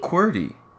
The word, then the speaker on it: QWERTY: How do you say that? QWERTY? – QWERTY.